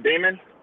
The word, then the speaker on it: Damon: Damon.